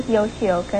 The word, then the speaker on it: Yoshioka: And to make it easier, I told them it was Mrs. Yoshioka, and it rhymes with tapioca.